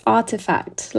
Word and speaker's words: RTFKT: So it's artifact, like an artifact.